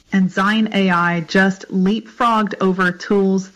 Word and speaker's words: Dzine: By the way, Zine is spelled D-Z-I-N-E, Zine, silent D.